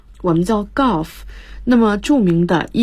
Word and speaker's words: Gulf: Gulf 和 Gulf 好像发音很接近的哈，跟高尔夫那个词，对，高尔夫发成 Golf，这个发成 Gulf，一道注意是三角的啊的发音。